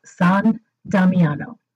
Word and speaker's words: San Damiano: It's actually Italian and it's pronounced San Damiano. So that's San for saint and Damiano, which is Damien, which is the church where St. Francis heard God speaking to him from the crucifix.